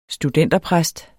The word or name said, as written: studenterpræst